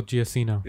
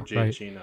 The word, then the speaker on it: Giacchino: It was done by Michael Gigliano. Is that how you say his name? – Maybe. I don't know. – Michael Giacchino. – Yeah. – Sorry. The music was done by Michael Giacchino.